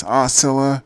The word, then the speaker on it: Aucilla: That's Osceola, spelled Osceola, rhymes with Osceola.